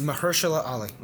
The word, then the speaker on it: Mahershala: Like Rose Byrne, Mahershala Ali. – Yeah. – I think I say it different every time I say his name. – Mahershala. – Mahershala? – It's Mahershala Ali.